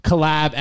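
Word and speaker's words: CollabXO: So tell us a little bit about Collab XO and how you started it. Is that how you say it? Well, it's Collab. It's like XX. – No, the X is just silent.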